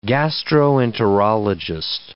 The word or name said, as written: gastroenterologist